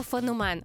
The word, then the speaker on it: феномен: Якщо мова про явище, то феномен. Обов'язково тільки феномен.